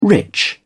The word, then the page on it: rich: British English